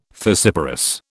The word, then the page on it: fissiparous: British English